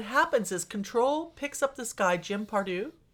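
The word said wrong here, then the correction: Prideaux: Am I saying that right? – Jim Prito.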